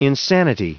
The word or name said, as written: insanity